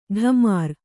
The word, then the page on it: ಢಮಾರ್: Kannada